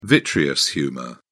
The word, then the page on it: vitreous humour: British English